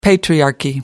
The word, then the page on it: patriarchy: American English